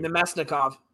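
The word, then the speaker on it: Namestnikov: Nemesnikov.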